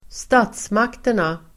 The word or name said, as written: statsmakterna